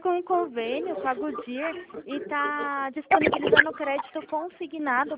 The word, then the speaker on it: Goodyear: É Goodyear.